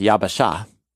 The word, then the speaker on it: yabasha: It's pronounced yabasha, the yabasha.